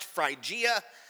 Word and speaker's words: Phrygia: After spending some time there, he departed and went from one place to the next through the region of Galatia, and that other word is pronounced Phrygia, French Phrygia, Phrygia, and it says strengthening all the disciples.